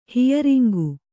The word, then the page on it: ಹಿಯರಿಂಗು: Kannada